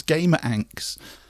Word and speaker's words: GameRanx: and I always thought it was called Gamer Anx.